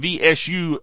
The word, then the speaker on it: V-Subst: You try pronouncing V-S-U-B-S-T. V-Subst.